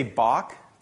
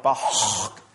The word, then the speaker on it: Bach: If you think of the German CH, like in the name, we would say Bach, but if you pronounced it with the guttural properly, it'd be more like Bach, right?